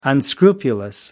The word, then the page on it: unscrupulous: American English